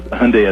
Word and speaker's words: Hyundai: So it was Sunday as Hyundai as in Sunday, and we did it on Super Bowl Sunday, And I had so many people come up to me afterwards.